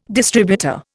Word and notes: distributor: Indian

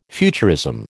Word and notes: futurism: American English

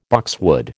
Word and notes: boxwood: American English

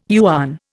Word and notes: yuan: Indian

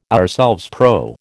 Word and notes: ourselves: American English

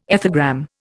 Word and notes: ethogram: Indian

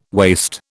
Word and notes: waist: British English

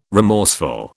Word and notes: remorseful: British English

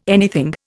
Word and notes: anything: Indian

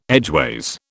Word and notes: edgeways: British English

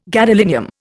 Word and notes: gadolinium: Indian

